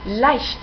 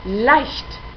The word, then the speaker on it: leicht: En segundo lugar, la CH se pronuncia casi siempre como J. Fijaos en la pronunciación de leicht, leicht.